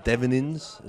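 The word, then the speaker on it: Devenyns: He was away towards the end with Dries Devenins. Is that how you pronounce his name? – Yep.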